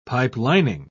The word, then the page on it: pipelining: British English